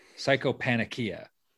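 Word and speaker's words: Psychopannychia: But how do you say it? Psychopanakia. And okay, I believe you.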